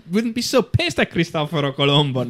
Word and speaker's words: Cristoforo Colombo: Cristoforo Colombo. – Cristoforo Colombo. – How you have proper pronunciation of we call Christopher Columbus. – Say it sounds so sexy when you say Christopher Columbus.